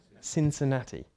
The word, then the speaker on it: Cincinnati: Cincinnati.